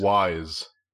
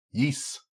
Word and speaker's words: Ys: I learned in this Nintendo Direct that I have been pronouncing Yees wrong my entire life. Because I've been pronouncing it Wise.